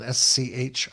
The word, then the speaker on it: Schramm: name is Jeff Schramm. Last name is spelled Schramm.